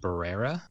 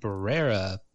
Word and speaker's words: Barbera: So it's from Northern Italy, probably more close to pronounced Barbera, given that it's Italian.